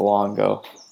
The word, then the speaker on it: Longo: Yes, that's correct. Longo. L-O-N-G-O.